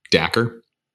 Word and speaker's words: Dacre: Dacre? – Dacre.